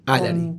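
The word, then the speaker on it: Álari: Y además, no Alari, sino Alari, Alari. – Con acento en la A, pues sí, tiene un toque muy personal.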